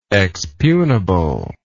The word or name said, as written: expugnable